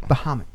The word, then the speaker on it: Bahamut: Bahamut.